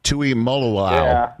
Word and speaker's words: Tuimoloau: You treated this segment like JT Tuimoloau treated Penn State. Did I say the name right? Tuimoloau, right? – Yeah.